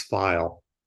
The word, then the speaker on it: Pfeil: And it was silly at times, because my daughter's last name is File. And it's PFEIL. So a lot of people mispronounce it.